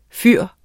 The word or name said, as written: fyr